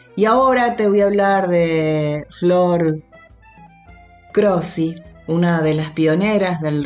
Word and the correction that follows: Croci: Eh, ella es una una Flor Croci, eh, Flor Croci, se escribe Croci pero se pronuncia Croci, lo dije mal yo,